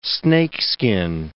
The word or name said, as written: snakeskin